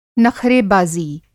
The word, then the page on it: नख़रेबाज़ी: نخرے بازی